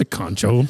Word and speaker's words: concho: What's a concho? Did I say it right? – Yeah, you got it.